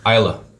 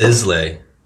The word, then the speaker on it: Islay: And how do you how do you say this word again? Isla. Isla. Okay, learning something new. I've always said Islay, which is Islay.